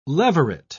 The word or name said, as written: leveret